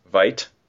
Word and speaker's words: Veidt: Veidt. Yeah. – Conrad Veidt from The Man Who Laughs.